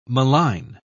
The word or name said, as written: malign